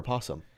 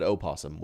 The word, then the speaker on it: opossum: Is it the opossum or possum? Are they both? – It's always spelled opossum, but it's always pronounced possum. It's just silent O.